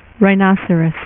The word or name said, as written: Rhino